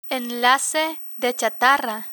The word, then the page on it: enlace de chatarra: Spanish